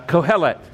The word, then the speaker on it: Kohelet: Is anybody doing the Kohelet? That's how you spell it, and that's how it's pronounced, Kohelet.